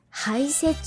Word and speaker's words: 排雪: 排雪とは外へ押し出すとか押しのけるという意味の漢字で、手辺に非常口の非と書く漢字、排除とか排水とかに使われる漢字、排という字に雪と書いて排雪と読みます。